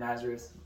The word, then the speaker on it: Nazareth: And back then, Nazareth, but, well, I just lost how to say that word.